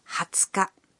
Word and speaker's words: 20日: Another exception is the 20th. It's pronounced 20日.